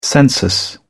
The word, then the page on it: census: British English